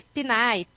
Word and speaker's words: PNAE: Eu ia dizer PNAE agroecológico, mas eu não sei se está certo, correta a pronúncia. – Tá correto, é porque PNAE, PNAE é o nome do Programa de Alimentação Escolar do Brasil.